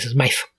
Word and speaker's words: Smythe: Sí, es Smythe.